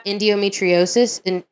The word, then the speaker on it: endometriosis: people that have endometriosis, is that yeah.